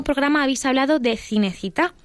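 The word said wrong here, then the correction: Cinecittà: pero su pronunciación no fue muy afortunada la, la pronunciación adecuada sería Cinechita.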